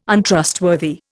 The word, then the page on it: Untrustworthy: Indian